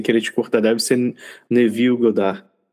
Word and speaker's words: Neville: É Neville, eu sei que é a pronúncia essa, Goddard, que é a minha pronúncia de brasileiro do sobrenome dele.